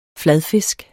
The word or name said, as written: Fladfiskene